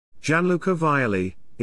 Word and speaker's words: Gianluca Vialli: Gianluca Vialli, Italian pronunciation, Gianluca Vialli,